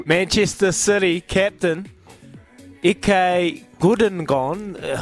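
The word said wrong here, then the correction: Ilkay Gündoğan: Hopefully I've said that right. – Ilkay Gundogan. – Gundogan.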